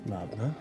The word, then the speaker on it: Laudna: Laudna.